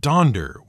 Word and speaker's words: Donder: He also, apparently, thought Dunder wasn't really the right spelling, since almost everyone was pronouncing it Donder, with an O and not a U.